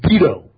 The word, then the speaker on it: Beto: It's Beto. It, like in veto. That's how you say it in Spanish. You say veto.